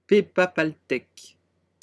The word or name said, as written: pepapaltec